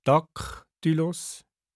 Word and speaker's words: Daktylus: Beim Daktylus und beim Anapest erklärt die Aussprache die Bedeutung. Beim Daktylus Daktylus, beim Anapest Anapest. Harmonie ist ein Anapest.